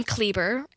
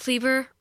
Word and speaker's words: Kleber: My last name, Kleber, it rhymes with Justin Bieber. That's just what I tell people. Like, what's your name, Paige? Paige Kleber rhymes with Justin Bieber.